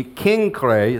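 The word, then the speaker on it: Cenchreae: And you can see that Synchrae, which the Greeks pronounce it with a hard See, Kinkrae is how they say it.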